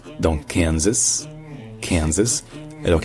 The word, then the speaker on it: Kansas: Dans Kansas, en fait, l'accent tonique est sur le can, donc c'est Kansas.